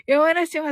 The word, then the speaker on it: 月見里: 山梨正義さん、ね、月見里と書いて、山梨と読むと、はい。